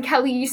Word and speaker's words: Kelli: I go by my middle name, by Aspen Kelly Summers in normal life, but in my acting name, I don't use the whole name because it's spelled K-E-L-L-I and nobody spells it right.